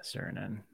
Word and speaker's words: Suriname: Those countries are Bolivia, Brazil, Chile, Colombia, Honduras, and Nicaragua, Peru, Uruguay, and how do you pronounce that? Suriname. – Yeah, Suriname.